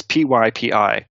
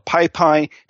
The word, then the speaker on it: PyPI: The Python Package Index, the abbreviation is PYPI. And it's pronounced PyPI, but I've heard so many people call it PyPy, Hippie, Pipey, Peepee. I've heard every different way of saying it.